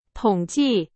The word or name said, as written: 统计